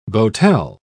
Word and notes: boatel: British English

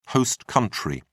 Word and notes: host country: British English

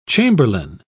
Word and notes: chamberlain: British English

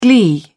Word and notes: glee: British English

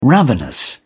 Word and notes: ravenous: British English

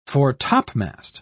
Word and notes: fore-topmast: British English

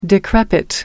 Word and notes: decrepit: American English

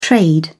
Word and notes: trade: British English

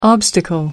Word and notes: obstacle: American English

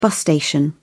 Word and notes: bus station: British English

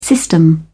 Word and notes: system: British English

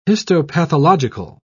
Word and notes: histopathological: British English

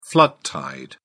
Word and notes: flood tide: British English

